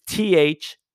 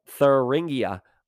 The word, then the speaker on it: Thuringia: The Germany city where they're locked and loaded with your boy Roy is Thuringia, which is Thuringia. Like a Thursday, like Thuringia.